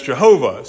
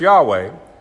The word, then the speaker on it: Yahweh: For a long time, people pronounced it as Jehovah. So if you hear Jehovah, that's the same thing. But we understand now it probably was pronounced Yahweh.